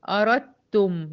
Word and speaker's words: أردتم: أنا قلت وإن، أدي توسط النون، أردتم، دخلت من الريه على التاء المشددة على طول، الدال ما اتنطقتش في الكلمة دي زي عبدتم، هنا أردتم من الريه على التاء شدة وضمة.